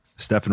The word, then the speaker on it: Stefan: Stefan rhymes with Effin.